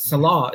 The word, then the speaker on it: Selah: But Selah, if I'm saying that right. – Yes, that's correct.